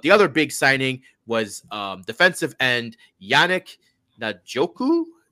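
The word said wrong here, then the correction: Ngakoue: It is Ngakwe. I knew it was Ngakwe.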